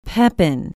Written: pepin

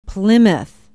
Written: plymouth

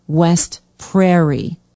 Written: west prairie

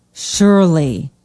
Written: Shirley

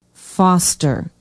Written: Foster

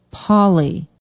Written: polley

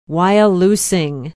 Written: wyalusing

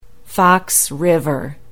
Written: Fox River

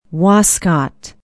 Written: wascott

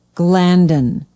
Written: glandon

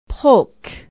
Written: polk

